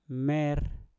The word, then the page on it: mer: Mpur